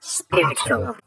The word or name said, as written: Spiritual